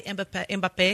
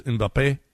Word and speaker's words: Mbappé: You have in one side this guy Mbappé.